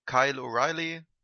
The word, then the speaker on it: Kyle O'Reilly: Wenn man mal so die Top-Guys wie Adam Cole, Ky Kyle O'Reilly, habe ich den richtig ausgesprochen? – Mhm.